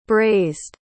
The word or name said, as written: brazed